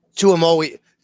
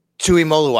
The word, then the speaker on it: Tuimoloau: How do you say the edge rusher from Ohio State's name? Say that again. – JT Tuimoloau. Tuimoloau.